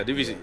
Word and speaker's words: Divisi: Like uh, speaking of like different sounds and stuff like that, how did your uh, collaboration with is it uh, Divizi? – Oh, Div- yeah, Divisi.